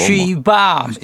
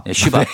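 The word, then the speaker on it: She Bop: 뭐 쉬밥 뭐. 쉬밥 쉬밥 네. 쉬밥을 바로 오빠로 이제 바꾼 예, 예. 건데. 발음 발음 좀 좀잘 좀. 아, 그래요? 발음 좀 조심해. 쉬, 밥 쉬밥.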